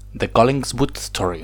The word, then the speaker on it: The Collingswood Story: a ver si lo pronuncio bien, The Collingswood Story. Lo has dicho bien, lo has dicho bien.